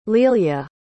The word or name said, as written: lilja